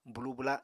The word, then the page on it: bwlu-bwla: apatani